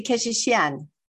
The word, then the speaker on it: Keshishian: Yeah, Armineh, exactly, Keshishian.